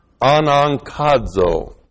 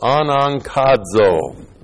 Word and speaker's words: anankazo: And compel us is the present active indicative of the verb anankazo, which looks like this in the Greek. That's A-N-A, two gutturals together. The first one sounds like an N. K-A-Z long O. Anankazo. Anankazo means to compel, it means to force, it means to bully.